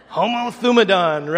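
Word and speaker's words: Homo Thumadon (ὁμοθυμαδόν): Everybody remember how to pronounce that? Homo Thumadon, right? Sounds like a great woolly mammoth just roaming across the plains.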